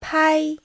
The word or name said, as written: pai1